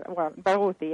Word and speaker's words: Barghouti: There's a gentleman, Mr. Barghouti is one of the sponsors, is he not? – Mr. Barghouti? – Did I get his name right? A Palestinian? – That's right. You did have, Barghouti, yes.